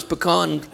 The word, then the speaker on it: pecan: It's like saying of a tree, apple tree, pecan tree, and that's the correct way to pronounce pecan, you know, because we're from Texas, we understand, is that tree has a disposition, orientation, or you could say a spirit.